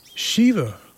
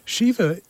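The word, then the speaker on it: Siomha: And Shiva is also on Substack, and that's where I found her. And it's spelled not like it sounds. It's S-I-O-M-H-A, music.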